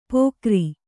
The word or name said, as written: ಪೊಗರಿ